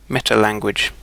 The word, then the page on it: metalanguage: British English